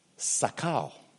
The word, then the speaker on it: sakal: In the Hebrew, it is dea, that is D-E-A-H, and sakal. S-A-K-A-L. I'm not an expert in Hebrew, so this is my best Afro-British pronunciation I can come up with.